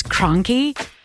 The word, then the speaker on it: Kronke: S-C-H-E-M-A-N. It's spelled like Scheman. – My last name is Kronke, and it's very German. And everybody always says either Crank or Kranky.